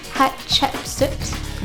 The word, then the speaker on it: Hatshepsut: Hatshepsut.